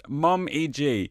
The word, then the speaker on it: Mom-G: We're Mom-G, pronounced Mom-E-G.